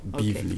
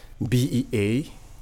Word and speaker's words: Beavly: Am I pronouncing it right? Is it Bizly? – Yes, you are. Bizly. – Okay. Now spell it for me. – Bizly.